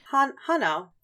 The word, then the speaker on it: Hanau: At the time, it was called Hanau. Ugh, that's not how it's pronounced. Hanau? H-A-N-A-U. – Hanau. – Hanau, yeah. They were born in this town, and their parents had 9 children, only 6 of whom survived, because that was pretty standard.